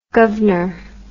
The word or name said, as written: guvner